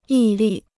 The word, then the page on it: 毅力: yì lì